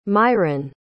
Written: miron

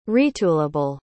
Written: retoolable

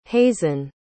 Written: hazen

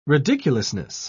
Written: ridiculousness

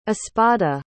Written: espada

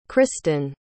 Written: cristen

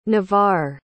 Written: navarre

